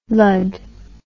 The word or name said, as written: lud